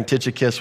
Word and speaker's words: Tychicus: We coordinated, by the way, the pronunciation of Tychicus. You can hear it like lots of different ways, but we're going to roll with that this morning. Tychicus, we'll learn a little bit about him.